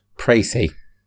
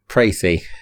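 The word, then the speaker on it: précis: If you don't know what Metal Gear Solid is, my precise, how do you pronounce that word? – Precy. – So neither of the options I said. Say it again. – Precy.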